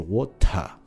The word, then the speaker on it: water: 那么比如说像这个 water 这个词，那么呃英国人呢，大部分的英国人呢，或者说标准的发音是 water，会把这个 t 这个音发的很重，但是伦敦人呢，会直接就把这个 t 就省略掉，就变成 water。